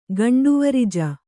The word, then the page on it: ಗಂಡುವರಿಜ: gaṇḍuvarija